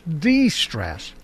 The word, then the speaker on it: de-stress: De-stress. Yeah. You have to put it on the right syllable there. De-stress.